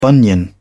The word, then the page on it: bunion: British English